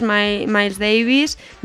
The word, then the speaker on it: Miles Davis: El gran trompetista de jazz, Miles Davis, visitó el festival en Lo dices muy bien, ¿eh? – ¿Eh? Lo he dicho bien, ¿no? – Muy bien, sí, sí.